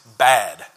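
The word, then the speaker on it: bad (בד): The Hebrew word for alone is bad. That is how it's pronounced. That is how it is written, is the word bad.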